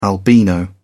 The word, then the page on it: albino: British English